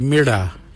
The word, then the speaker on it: mirda: Walang is spelled W-A-L-Y-A-N. Walang. Walang is blue. The next colour is red. In our language, we say mirda. Mirda is red. Mirda is spelled M-I-R-D-A.